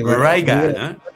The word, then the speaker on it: barriga: No, Bariga? Eh, Barriga. – Barriga. – Casi ¿eh? Barriga.